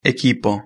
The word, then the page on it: equipo: Spain Spanish